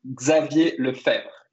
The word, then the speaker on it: Xavier Lefebvre: So my name is Xavier Lefebvre in French, which is not easy to say.